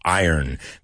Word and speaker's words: Iron: Fear of the Dark by Iron Maiden, La Doncella de Hierro, Iron. Cuidado con la pronunciación, no es Iron, it's Iron. ¿Tú sabes decir yo gano dinero, Iron Money? Pues así se dice, Iron, Iron, Iron.